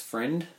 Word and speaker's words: Friend: It's not French or German, as a lot of people think. My surname isn't Freind. It's Friend.